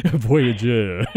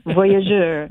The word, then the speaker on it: Voyageur: I need to pronounce that the Northland way. Voyageur. – Voyageur. – Right, and more French.